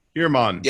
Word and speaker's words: Heermann: Heermann.